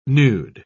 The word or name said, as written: nude